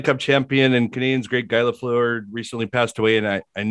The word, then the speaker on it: Guy Lafleur: Okay, well, first that he pronounces it Guy Lafleur.